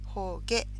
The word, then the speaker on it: 放下: 放下と読みますね。話す、したと書いて放下です。